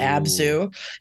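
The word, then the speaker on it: Abzû: believe it's pronounced Ooh. Abzu.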